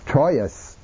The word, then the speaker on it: Troyes: and in Old French they would have said Troyes, Troyes. In Old French, all of the consonants are pronounced. And you don't have the wa. The wa comes into French at the time of the French Revolution.